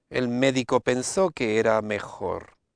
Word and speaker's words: médico: Médico. – Médico, and it has that I sound.